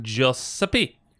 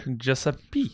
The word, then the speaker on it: Giuseppe: Is that how you pronounce it? – Giuseppe. – Joseph Fier? – Giuseppe.